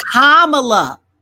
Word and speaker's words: Kamala: Kamala. – Wait, is doesn't it rhyme with Pamela? – No. – No? – Because her mama's name is Shamala. So they named her Kamala. It's Ka, Kamala.